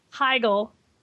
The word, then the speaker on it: Heigl: Heigl.